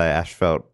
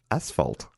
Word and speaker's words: asphalt: And I looked into it and it looks like it's, for some reason, Australia and Canada is majority of people say Ashfelt, but we say it wrong. There's no H in that spot. It's asphalt.